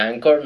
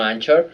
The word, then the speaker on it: Anchor: Sí, por cierto, por ahí abajo, si están en la aplicación de Anchor, que se pronuncia Anchor, no Anchor,